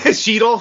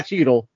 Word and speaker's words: Cheadle: Yeah, Dave Shadell. Uh, How do you say his name? Cheadle, Sheddle. Don Cheadle. Sorry for not Dave Chappelle. Don Cheadle.